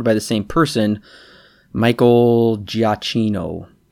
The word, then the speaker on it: Giacchino: Giacchino is how you pronounce it.